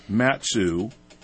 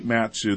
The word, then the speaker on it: Matsu: Matsu Courthouse. Robert T. Matsu, that's spelled M-A-T-S-U, 501 I Street, Courtroom 3, and that's on the 15th floor.